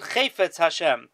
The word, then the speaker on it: Chefetz Hashem: Now, the Velt says that you pronounce it Chefetz Hashem,